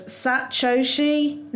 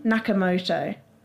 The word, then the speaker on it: Satoshi Nakamoto: So it was proposed in this paper, which the pen name of a person who wrote the paper was Satoshi Nakamoto. Now, I might have said that wrong. It's S-A-T-O-S-H-I. Satoshi, then N-A-K-A-M-O-T-O. So Nakamoto.